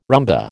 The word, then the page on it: rumba: American English